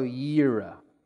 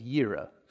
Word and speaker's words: Jireh: Jehovah we would, I, would have, I would have known it this way Jehovah Jireh. I barely know how to pronounce it the other way. Jehovah Yireh, I think is how it is. Yireh. Jehovah Yireh. It means the Lord will provide.